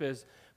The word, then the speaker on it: Ai: is, but they move north into Ai. It's A-I. It's pronounced I, up north.